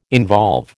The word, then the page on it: involve: American English